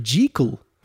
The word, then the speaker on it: Jekyll: En u hoort dat goed, want eigenlijk de juiste pronunciation is Dr. Jekyll. En niet Dr. Jekyll.